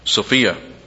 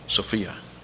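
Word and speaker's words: Sophia (σοφία): And he uses all through here for wisdom, Sophia. Sophia. Wisdom. Actually, that's not a long O, it's just an Omicron. Sophia.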